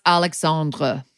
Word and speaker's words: Alexandre: If this is in France, I think it would be pronounced Alexandre.